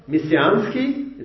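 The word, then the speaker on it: Messiansky (Мессианский): In Russian, Martian and Messianic are spelled similarly and are pronounced almost the same. Messiansky, it's Messianic. Marciansky, Martian.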